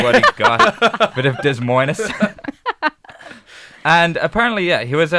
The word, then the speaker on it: Des Moines: Des Moines, Iowa. – Des Moines. – Des Moines. – Des Moines. – Yeah. Okay, fair enough. I got it fairly right. – That's how it's pronounced, Des Moines.